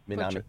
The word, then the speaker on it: Minami: So I'm sorry if I Minami. You know, you got it.